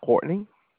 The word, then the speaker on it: Courtney: My name is Courtney. – Courtney? How do you spell that? – C-O-U-R-T-N-E-Y. – Just like it sounds, okay.